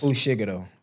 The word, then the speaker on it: Fushiguro: Fushiguro.